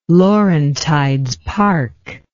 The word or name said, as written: Laurentides Park